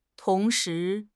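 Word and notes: tong2 shi2: tones 2, 2

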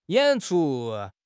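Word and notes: yan3 chu1: tones 3, 1

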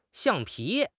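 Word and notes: xiang4 pi2: tones 4, 2

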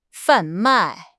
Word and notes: 贩卖: HSK6

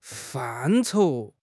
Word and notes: 范畴: HSK6

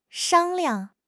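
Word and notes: shang1 liang5: tone 1; neutral tone

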